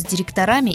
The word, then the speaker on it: директора: Говорить нужно «доктора», «профессора» и «директора». Других вариантов ударения в этих словах быть не может.